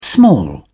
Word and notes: small: British English

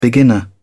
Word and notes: beginner: British English

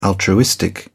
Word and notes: altruistic: British English

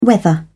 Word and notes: weather: British English

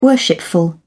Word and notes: worshipful: British English

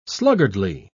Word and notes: sluggardly: British English

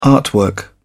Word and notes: artwork: British English

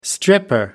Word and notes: stripper: American English

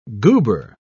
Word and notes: goober: British English

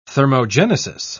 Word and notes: thermogenesis: British English

